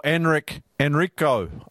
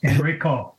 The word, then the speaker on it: Enrico: Or Enrico Arnaud. Is that how you'd say his name in the language? – Yes, it is. Enrico.